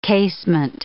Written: casement

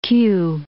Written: Kew

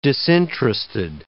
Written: disinterested

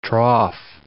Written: trough

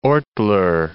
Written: Ortler

